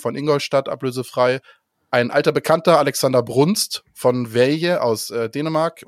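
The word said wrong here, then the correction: Vejle: Oder wie spricht man richtig aus, Nando? Äh, Nando, äh, Bürger. Weile. Weile, aus Weile äh, ist gekommen.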